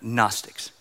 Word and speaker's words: Gnostics: Pretty sure the G is silent, so I'm going with a silent G on that. Gnostics.